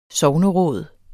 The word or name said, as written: sogneråd